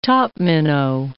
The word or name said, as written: topminnow